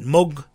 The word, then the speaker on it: Moog: Actually, it's spelled M-O-O-G, as in Armenian, the mouse, Moog. And I heard Mr. Moog talk about it. He said in Dutch, M-O-O-G, from many years ago, was called Moog.